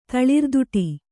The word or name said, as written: ತಳಿರ್ದುಟಿ